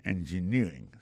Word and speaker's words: engineering: Engineering, ça, l'accent tombé le même sur avant dernière syllabe là.